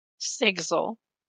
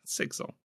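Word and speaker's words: Sigzil: What's the audiobook say? – Sigsel. – Yeah, Sigsel.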